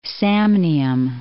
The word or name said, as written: Samnium